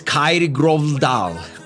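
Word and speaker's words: Kyrre Gørvell-Dahl: Kygo, cuyo nombre real es Kyrie Grovdahl. Así se escucha, así se escribe y así se pronuncia.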